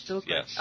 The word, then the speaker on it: Bilquis: Bilquis. – Oh, I'm sorry. I added a syllable.